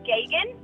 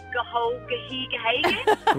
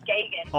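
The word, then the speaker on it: Geoghegan: His name is Gagan. So it's G-E-O-G-H-E-G-A-N, and nobody knows how to spell it. – How do they usually pronounce it? – Like Gahogahegahegan? – Grogan? – Geogatan? – Yeah. Well, yeah, his last name is Gagan.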